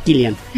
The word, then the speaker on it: Cillian: Cillian Murphy, que yo le decía Cillian, pero dice Luis que se pronuncia Cillian.